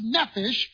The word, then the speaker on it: nephesh: The Hebrew word for soul is pronounced nephesh,